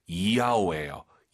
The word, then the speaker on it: 要: 要. – 여러분 그거 아셨어요? YAO 발음할 때 한국인들이 보통 야오 야오 하시는데요. 틀린 발음이에요. Y를요 항상 I로 바꾸세요. 정확히 발음하면요 이야오예요.